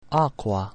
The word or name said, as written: aqua